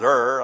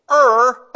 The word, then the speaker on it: Ur: The only way I know how to pronounce it is Ur. I guess that's right.